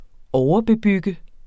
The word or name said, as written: overbebygget